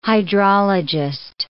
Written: hydrologist

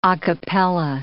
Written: a capella

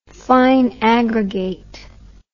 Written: fine aggregate

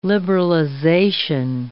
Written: liberalization

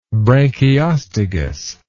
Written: branchiostegous

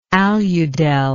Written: aludel

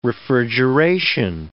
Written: refrigeration

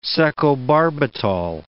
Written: secobarbital

